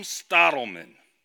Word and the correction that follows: Stoltman: Stoltman, okay. Tom Stoltman is the reigning World's Strongest Man.